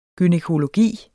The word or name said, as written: gynækologi